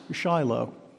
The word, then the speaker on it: Shiloh: Well, many attempts have been made to understand the meaning of this little Hebrew word, Shiloh, that we pronounce Shiloh.